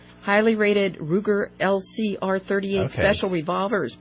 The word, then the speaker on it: Ruger: Ruger. – Yes. – R-U-G-E-R.